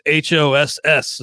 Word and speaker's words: Haas: I've mispronounced his name before, but I'm looking at Baseball Reference right now, and it is Hoss is the pronunciation, which is perfect because he's been a Haas so far.